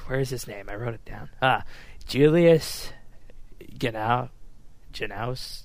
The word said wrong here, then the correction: Genachowski: How would you say that last name? – Genachowski.